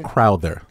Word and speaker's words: Crowther: And Crowther, is how you say it? – Crowther. – Crowther. – Like Souther.